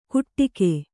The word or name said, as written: ಕುಟ್ಟಿಕೆ